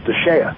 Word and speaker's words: DSHEA: which is commonly known by its initials, which are pronounced DSHEA. So people talk about DSHEA,